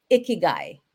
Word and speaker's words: Ikigai: Well, so what is Ikigai? Did I pronounce that correctly? – You did.